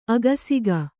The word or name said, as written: ಅಕ್ಷಕ